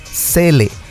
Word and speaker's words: Zelle: Se llama Zelle y se escribe Z-E-L-L-E, Zelle.